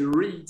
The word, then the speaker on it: Reid: C'est Reed, c'est bien prononcé.